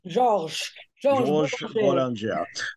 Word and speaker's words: Boulanger: Georges Boulanger.